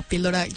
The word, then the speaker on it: Píldora: En Instagram, si quieren darse una vuelta por allá, nos pueden encontrar como _píldora_. En Spotify, Apple Music, la plataforma de streaming de su, bueno, de música, de su preferencia, nos pueden encontrar como Píldora con acento en la I.